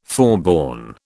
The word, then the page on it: forborne: British English